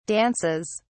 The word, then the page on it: dances: British English